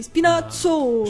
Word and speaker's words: Spinazzola: I pugliesi lo pronunciano così, Spinazzola.